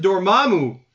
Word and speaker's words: Dormammu: He says, I've always pronounced it Dormammu, like they say in the movie.